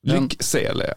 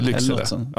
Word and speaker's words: Lycksele: Lycksele, inte Lycksele.